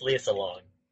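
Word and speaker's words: Lea Salonga: And it's also pronounced Leah Salonga.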